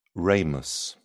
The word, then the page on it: ramus: British English